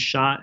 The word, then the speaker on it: Shah: So I spell it N-E-E-L. And my last name is Shah, S-H-A-H.